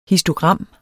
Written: histogram

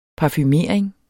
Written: parfumering

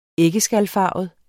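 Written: æggeskalfarvet

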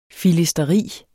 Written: filisteri

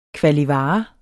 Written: kvalivare

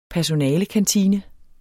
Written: personalekantine

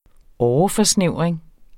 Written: åreforsnævring

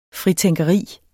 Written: fritænkeri

